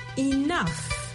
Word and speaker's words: enough: Le mot du jour, enough. Ça s'appelle E-N-O-U-G-H. Ça s'entend comme E-N-O-F. Le G-H en anglais se prononce souvent comme un F. Et le O-U, en fait, ça se prononce comme un O.